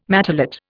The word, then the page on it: matelot: Indian